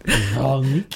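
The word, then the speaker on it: Véronique: And her name is Veronique. – Veronique. – Is that how you say it? – Veronique. – Veronique. Veronique. Oh, yeah. She's so much better. Veronique. – There you go. Almost. Oh. It's very French.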